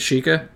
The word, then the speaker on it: Sheikah: Like, and also I think they could potentially make games about the Sheikah tribe. How do you say it? – The Sheikah? – Yeah.